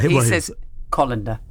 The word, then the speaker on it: colander: Yeah, he says... colander.